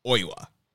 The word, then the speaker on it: Oiwa: And to me, it sounded like he was saying Oiwa. – To me, it sounds like he's saying Oiwa as well. And then I used 4 or 5 different pronunciation websites, even ones that have, like, variants. Like, you could put in the French pronunciation, the English pronunciation, you know what I'm saying? And then they have one specifically for Japanese, and they all say Oiwa.